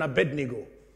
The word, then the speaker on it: Abednego: I like the way Americans pronounced it, Abednego. I've heard that many times. I don't know why they can't pronounce the Nigo. They say Ingo, Abednego. That used to make me laugh.